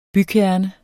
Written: bykerne

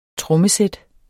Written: trommesæt

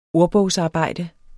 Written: ordbogsarbejde

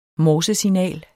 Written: morsesignal

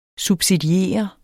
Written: subsidier

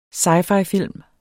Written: sci-fi-film

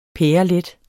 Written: pærelet

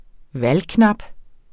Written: valgknap

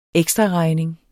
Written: ekstraregning

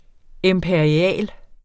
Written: imperial

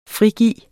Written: frigive

